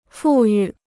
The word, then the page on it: 富裕: fù yù